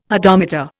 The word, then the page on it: hodometer: Indian